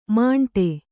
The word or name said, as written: ಮಾಂಟೆ